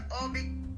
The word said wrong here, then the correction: Oubre: Kelly Oubre, I'll look up his last name, how you pronounce. Yeah, Kelly Oubre, that's how you say his name.